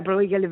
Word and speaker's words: Bruegel: Ridimmi Bruegel? – Peter Bruegel vecchio. – Ah, questa pronuncia fantastica.